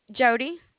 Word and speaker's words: Jodi: Jody. – Jody, how do you spell it? – J-O-D-I. – J-O-D.